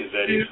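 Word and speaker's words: Yezidi: Yazidi, it's Y-E-Z-I-D-I. Yazidi. Yazidi.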